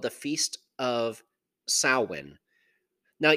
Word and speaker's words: Samhain: It's usually spelled, however, a little differently than it's pronounced. It's spelled, if you're looking at it in English, Samhain, so Samhain. However, it's a Gaelic word. It's pronounced Samhain.